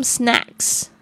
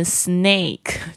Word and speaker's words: snack: Swimming suit OK Some snacks Some snacks 就是一些零嘴零食，因为怕饿嘛，因为可能到处要走动。 Snacks S-N-A-C-K，千万不要念成 snake，就会变成蛇哦。 Snack 它是这个 A 这个啊，舌头向下压的这个声音。接下来是 water W-A-T-E-R 水。 Socks。 S-O-C-K-S 是袜子，短袜子，特别是穿运动鞋也穿袜子比较不会脚不会受伤哦。